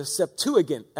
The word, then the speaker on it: Septuagint: You know, the Old Testament was originally written in Hebrew, but the ancients translated it into Greek, the Septuagint, as it's properly pronounced.